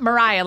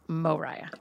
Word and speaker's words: Mariah: Because you're not Mariah like Mariah Carey. It's Mariah.